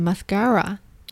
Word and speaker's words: mascara: 嗯，也会有人是念 mascara，也可以 mascara，mascara 也可以，就只是说那个 a 的发音不一样，一个是把 a 发成 a，一个是发成 a，对吧？mascara，mascara。